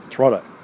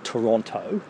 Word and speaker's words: Toronto: Got to say it right, Toronto. None of this Toronto.